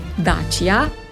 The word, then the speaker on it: Dacia: Zagotovo ne veste, kako se pravilno izgovori Dacia. To je blagovna znamka zelo znanih avtomobilov in jaz bi opozorila, da pravilno se reče Dacia.